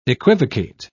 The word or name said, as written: equivocate